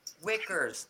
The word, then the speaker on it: Wickers: So I just noticed that Chrissy Witchers, is that how you say it, Witchers? – Wickers? – Wickers? Look, those of you that know her, because I've never actually met her in real life. How do you say her name? – Wickers, like the basket.